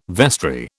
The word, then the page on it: vestry: British English